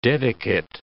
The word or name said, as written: dedicate